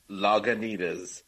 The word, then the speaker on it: Lagunitas: I'm telling you, it was funny as hell, but I don't know if it's going to play the same on the La Guanitas. – La Guanitas. Hold on, I missed out on that. How do you say it, Jess? – She did it again. Okay, so Jess keeps pronouncing. Lagunitas, Lagunitas.